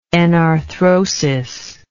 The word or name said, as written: enarthrodial